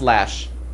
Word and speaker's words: Loesch: Something like that, but it's pronounced Lash.